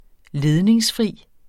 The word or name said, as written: ledningsfri